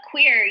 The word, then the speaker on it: Qweer: Did I say that correctly? – Queer, yeah. It's a mainly a networking group for queer women in entertainment. Q-W-E-E-R, which is so cheesy, but I couldn't think of a title.